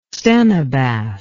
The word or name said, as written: stenobath